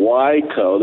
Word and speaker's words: Waco: He and Dana were in the great state of Texas here, and went up and visited them in Waco. That's how we pronounce it here, Waco.